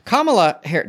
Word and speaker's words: Kamala Harris: Kamala Harris, did I say that right? – Yes.